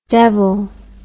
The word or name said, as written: devel